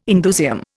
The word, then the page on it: indusium: Indian